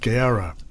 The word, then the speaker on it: Gawurra: It's spelled G-A-W-U-R-R-A. And pronounced Gowra is the name of the artist, and the track's called Divine of Love.